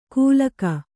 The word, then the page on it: ಕೂಲಕ: kūlaka